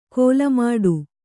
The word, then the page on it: ಕೋಲಮಾಡು: kōlamāḍu